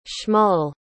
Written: shmuel

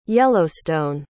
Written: yellowstone

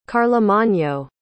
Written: carlomagno

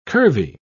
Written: curvy

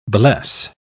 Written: bless

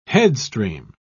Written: headstream

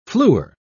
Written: fluor

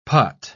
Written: putt

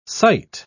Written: sight

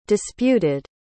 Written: disputed

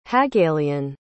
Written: hagalean

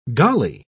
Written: golly

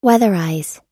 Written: weatherize

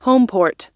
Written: homeport

